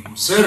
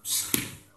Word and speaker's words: Гуссерль: Не Гуссерль, да, а Гуссерль. Произносить эту фамилию.